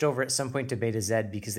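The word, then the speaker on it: Betazed: Also, I saw from the subtitles, it's like Beta Zed. I've always said Betazoid. – I think I've always said it wrong.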